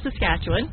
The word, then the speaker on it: Saskatchewan: Your family farms up in Saskatchewan. – Yes. – Did I say that correctly? – Saskatchewan.